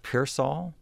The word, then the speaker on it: Pearsall: And Chet's group is going to be, I think it's Chet's group, is going to be doing some music by Robert Pearsall.